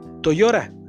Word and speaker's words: Toyota: En México lo decimos toyora perdón, Toyota, ¿okay? En inglés más o menos se pronuncia Toyota, ¿ok? O Toyora. ¿ok?